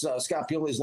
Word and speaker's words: Pioli: You know, I was like, I didn't even know how to pronounce Scott Pioli's last name, to be honest with you guys. I had to call the Jets receptionist first. I said, who's your director of pro scouting? She goes, Scott Pioli. I didn't know how to pronounce his name.